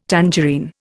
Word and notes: tangerine: Indian

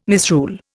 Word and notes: misrule: Indian

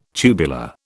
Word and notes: tubular: British English

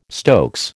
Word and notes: stokes: American English

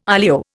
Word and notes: olio: Indian

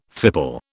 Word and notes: fipple: British English

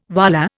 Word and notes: wallah: Indian

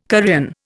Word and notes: Korean: Indian

